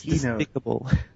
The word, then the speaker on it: despicable: Despicabye? I'm not even sure if I'm pronouncing it right. – How do you pronounce it, despicable? Or despicabye? – It's despicable. – It's despicable, oh.